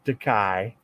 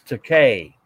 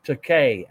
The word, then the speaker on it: Takei: Shatner always called him Takai, okay, and then but his real name is pronounced Takay, as in, and it's his way he would tell him. It's a Bill. It's pronounced Takay, as in toupee.